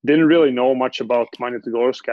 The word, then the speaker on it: Magnitogorsk: So how do you wind up in, is it Magnitogorsk? How do you pronounce that? – Yeah, that was good. The first one was good. Magnitogorsk.